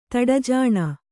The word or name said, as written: ತಡಜಾಣ